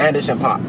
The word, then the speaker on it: Anderson .Paak: Anderson .Paak.